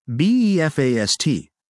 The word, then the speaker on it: BE FAST: BEFAST, pronounced Be Fast, adds two letters, B and E, to the former acronym FAST.